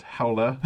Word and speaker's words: Harry Hole: Well, see, I'm getting to, you know, in the book, they say it's pronounced Harry Holy. – Well, that's fine. – And the publisher told to me personally on Twitter, because I kept giggling, that I'm reading a Harry Hole book. And he's like, no, it's pronounced Howla.